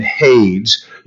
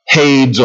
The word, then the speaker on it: Hades: Now, this, idea that Lazarus is at Abraham's side, you know, the rich man being in torment in Hades, you're going to read through the scripture and you're going to find that Hades, or Hades, as it is sometimes pronounced, is described several different ways.